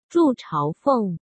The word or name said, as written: Zhu Chaofeng